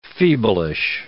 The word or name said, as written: feeblish